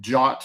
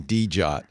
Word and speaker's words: Djot: It's called the JOT, spelled DJOT. – I'm glad to know how to pronounce that. All right. Yeah. I was saying DJOT.